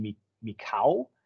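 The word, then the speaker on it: Michał: I believe it's pronounced Mikau. It's not an L. It's pronounced differently in Polish.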